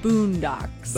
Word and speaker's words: Bundox: Like Bundocks, so okay. B-U-N-D-O-X, but okay. It's Boondocks.